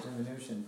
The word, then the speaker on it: diminution: If you'll notice, if you stick with Krishna consciousness, and I hope you do, you're going to hear devotees pronounce this word, diminution. Diminution. What's another one? – Diminution. – There's two or three, the way they say it, but it's diminution. It's a diminishing, that's all.